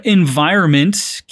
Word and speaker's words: environment: A palavra environment, que significa meio ambiente, pode ser um pouquinho difícil de pronunciar por causa do som de N seguido por M ali no meio.